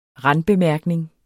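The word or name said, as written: RANDBEMÆRKNING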